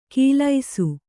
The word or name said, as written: ಕೀಲೈಸು